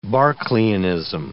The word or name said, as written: Berkeleianism